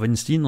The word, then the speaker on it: Weinstein: Je rappelle qu'on dit Epstein parce que c'est un Américain. On dit Einstein parce qu'il était allemand. Mais normalement, pour Epstein ou pour Weinstein, on dit comme ça parce que c'est comme ça qu'on prononce ça aux Amériques.